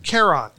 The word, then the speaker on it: Keron: Keron.